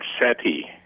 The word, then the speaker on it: SETI: And I want to ask you about right now, how do people pronounce SETI, CD? How's it said normally? – Oh, it's usually said SETI.